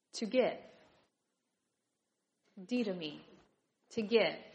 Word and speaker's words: didomi: The word is didomi. Didomi. Say it with me. Didomi. All right. It's spelled D-I-D-O-M-I. Didomi. Any guesses what language this word might come to us from?